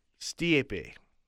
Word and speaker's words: Stipe: Thoughts on Stipe? Hashtag tweet the T-Mac, because hashtag tweet the table doesn't recognize my tweets. – Stiepe. – What? – Stiepe. – What is that? – That's how you say it.